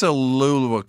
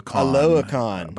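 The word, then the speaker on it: Oluokun: I think it's Aluakun. – Aluakun. – But I definitely didn't say that. Sounds way better than what I said.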